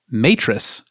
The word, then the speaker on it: Matrix: For those listeners who might be confused, I believe the typical pronunciation of that is the DJI Matrix, as in Matrix.